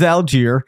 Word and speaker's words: Allgeier: Wasn't sure if it was Allgeier. It is Allgeier. I can confirm that.